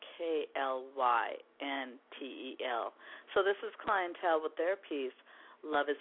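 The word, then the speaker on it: Klyntel: The group is named Clientele, an amazing, amazing band.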